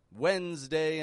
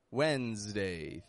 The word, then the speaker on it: Wednesday: S in both of these words sounds like a Z. Wednesday. Thursday. Wednesday is particularly difficult because the spelling, Wednesday, is not at all reflected in the pronunciation. Now let me explain to you real quick what has happened here. Why Wednesday is spelled with a D, which is not pronounced.